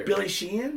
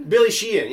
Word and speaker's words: Billy Sheehan: Who's Billy Sheeran? – Did I say his name wrong? – I don't know. – Bass player. – Billy Sheehan? – Billy Sheehan, yeah, sorry.